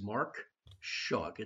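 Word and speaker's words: Mark Schug: My name is Mark Shug. It's spelled S-C-H-U-G.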